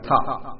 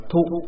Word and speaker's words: thọ: Ở trong miền Nam đọc là thọ, miền Bắc chúng ta đọc là thụ.